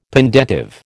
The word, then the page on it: pendentive: American English